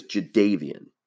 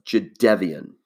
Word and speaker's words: Jadeveon: And first of all, and I wanted to confirm this morning, because I've always pronounced his name as Jadeveon, but it's actually pronounced Jadeveon.